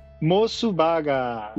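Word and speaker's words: MOS Burger: Como é a pronúncia japonesa? – Mosubaga. – Mosubaga. – É, se for pra falar em letras romanas, pra pesquisar, é M-O-S-S Burger.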